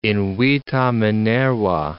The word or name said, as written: invita Minerva